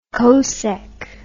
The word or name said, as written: cosech